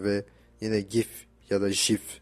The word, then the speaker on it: GIF: GIF değil JIF diye e, söyle, telaffuz ediliyormuş.